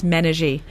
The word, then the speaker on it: Menaji: So it's pronounced Villielmo, but yes, Pamela Villielmo, and the name of the brand is Menage. – Menage. – Menage, mm-hmm. Yes. – M-E-N-A-J-I. – A-J-I, yes.